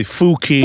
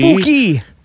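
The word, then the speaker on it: Fuki: I give to you Plum Fucky. Plum Fucky. I realize it's probably Fooky. – Fooky.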